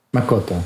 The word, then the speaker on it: Makoto: Makoto.